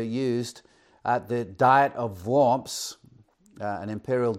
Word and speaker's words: Worms: But at the Diet of Worms, Worms being a town in Germany, we spell it in English W-O-R-M-S, we would say Worms, but in German it was Worms,